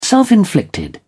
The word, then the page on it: self-inflicted: British English